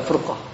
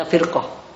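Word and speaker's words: firqah (فرقة): Jadi kita bacanya firqah, adalah kelompok. Tapi kalau dibaca furqah, itu kan berarti perpecahan. Ya.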